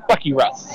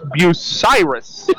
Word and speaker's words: Bucyrus: Now, Charlie does call the Bucky Russ. – No, he's a Bucyrus native. He knows how to pronounce it. It's Bucyrus.